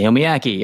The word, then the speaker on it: Naomi Ackie: Naomi Ackie, yeah.